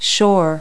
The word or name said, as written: shore